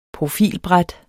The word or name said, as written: profilbræt